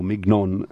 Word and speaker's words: Mignon: Viene fatto pronunciare da Goethe a Mignon, o Mignon si direbbe in tedesco, la ragazzina che Wilhelm incontra in un gruppo di danzatori di strada e decide di prendere sotto la sua protezione. Mignon, di origini italiane, ricorda con nostalgia il suo paese e diventa personificazione del desiderio del sud.